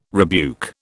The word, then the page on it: rebuke: British English